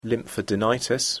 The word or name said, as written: lymphadenitis